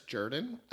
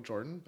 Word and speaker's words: Jordan: That makes 1942 by Clarence Jordan. It's spelled Jordan, like Michael Jordan, but he's from Georgia and he said Jordan.